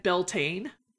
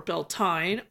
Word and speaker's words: Beltane: Oh, Welsh mare in foal, Brigid and Beltane, or Beltane, or Beltenia, depending on what dialect you're speaking.